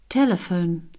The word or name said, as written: telephone